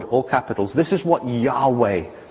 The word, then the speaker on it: Yahweh: Yahweh, the God, who makes promises and keeps them, the God who created, the God of the Old Testament, Yahweh, or Jehovah, as some would pronounce it, this is what he says.